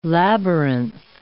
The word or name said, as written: labyrinth